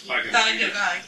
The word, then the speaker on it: Bhagavad Gita: But prior, long prior to that, the ancient rabbis claimed that all of the wisdom in the, which in the word I can never pronounce, the Bhagavad Gita, is that, did I say it? – Bhagavad Gita.